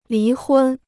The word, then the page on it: 离婚: lí hūn